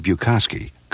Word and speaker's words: Buchkosky: I'm going to spell a name that many people find hard to pronounce, B-U-C-H-K-O-S-K-Y. Once again, B-U-C-H-K-O-S-K-Y. Now you try to pronounce it. If you said Bukowski, congratulations. You have properly pronounced not only the hardest, but one of the finest names in jewelry.